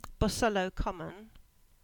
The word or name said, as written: Bosullow Common